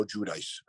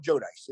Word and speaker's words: Giudice: I call him Joe Giudice. All right. That's the way we've always known to pronounce his name.